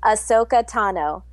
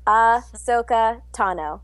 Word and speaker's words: Ahsoka Tano: Most people mispronounce my character's name. It's pronounced Ahsoka Tano on Star Wars The Clone Wars. So, Ahsoka Tano. – Ahsoka Tano. – Yeah. Yeah, not what? Tano, not Ahsoka, just Ahsoka Tano.